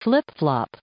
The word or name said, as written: flip flop